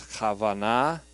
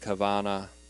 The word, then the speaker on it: kavanah: The Jewish pronunciation I saw pronounces it as Kavanah. I've also heard a more anglicized Kavanah, or even Kavanah.